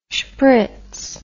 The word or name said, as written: shpritz